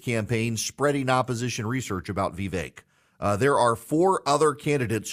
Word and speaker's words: Vivek: Vivek. Vake rhymes with cake. Vivek. The DeSantis people are helpfully pointing out Vivek rhymes with fake.